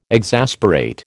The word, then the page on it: exasperate: American English